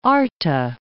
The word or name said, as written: Gulf of Arta